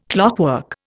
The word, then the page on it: clockwork: Indian